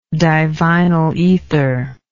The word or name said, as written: divinyl ether